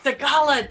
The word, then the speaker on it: Sigala: Sigala, dang it. Okay, I guess I needed to think more with a Hispanic slant.